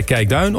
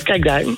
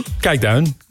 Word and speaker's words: Kijkduin: Zeg jij Kijkduin of Kijkduin? – Fort Kijkduin. – Kijkduin. Nou, oké, okay, dan zeg ik dat ook zo. Ja, het is net waar je de klemtoon legt natuurlijk. – Het mag allebei.